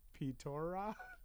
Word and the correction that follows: Pitra: It's Pitra. – Oh, Pitra.